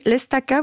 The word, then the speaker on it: L'Estaca: Donc, ça s'écrit l'Estaca, vous avez dit? – Exactement. Donc, L, apostrophe, mm-hmm. ensuite E-S-T-A-C-A.